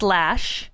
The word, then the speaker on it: Loesch: L-O-E-S-C-H. Is that how you say it? – That's how you say it, but it's pronounced Lash.